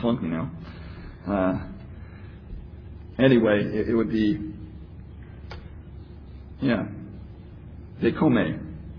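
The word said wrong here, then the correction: dekomai (δέχομαι): No, dekomai, because the AI is like an aisle.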